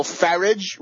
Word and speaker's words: Farage: Nigel Farage rhymes with garbage, and pretty boy blonde Boris Johnson, Thatcher's favorite journalist, and Lord Conrad Black's number one puppet.